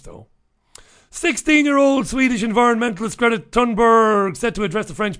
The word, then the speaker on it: Thunberg: They don't pronounce the TH. It's a hard T. Thunberg.